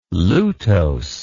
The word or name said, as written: lutose